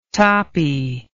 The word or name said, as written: toppie